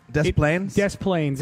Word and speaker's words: Des Plaines: Police said Foster, a Tallahassee resident, flew from Atlanta to Chicago before renting a car and heading to, I think it's pronounced Des Plaines. – Des Plaines.